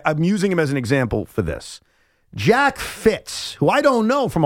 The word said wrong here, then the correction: Fritz: Well, you certainly don't, because you keep saying his name wrong. It is Jack Fritz. – Fritz, okay. Fritz. I'm sorry. – F-R-I-T-Z.